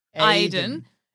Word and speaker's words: Aiden: And Cass is Iden, who is a ghost. And we work together. – Iden? – It's one of those, doesn't sound like a name. – It's spelled Aiden, but they say Iden. – But it doesn't. – I know. – A-I-D-E-N.